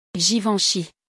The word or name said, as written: Givenchy